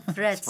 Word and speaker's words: Threads: Threads. – Je dôležité povedať th a d.